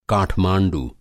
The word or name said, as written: काठमाँडू